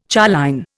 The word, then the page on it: choline: Indian